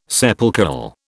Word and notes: sepulchral: British English

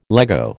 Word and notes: Lego: American English

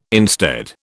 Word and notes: instead: British English

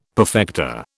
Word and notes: perfecta: British English

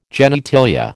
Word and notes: genitalia: American English

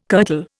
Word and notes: kirtle: Indian

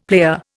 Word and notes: player: Indian

